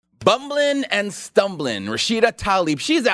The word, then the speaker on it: Tlaib: Rashida Tlaib, and it is pronounced Tlaib.